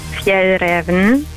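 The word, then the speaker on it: Fjällräven: Ich bin von der schwedischen Outdoor-Marke und es wird richtig ausgesprochen Fjell Raven.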